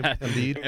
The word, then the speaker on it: Embiid: Embiid.